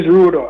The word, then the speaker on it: Rudo: All right, so the name is Rudo, R-U-D-O.